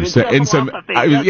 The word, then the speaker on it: encephalopathy: Encephalopathy. I think that's how you pronounce it. – Encephalopathy.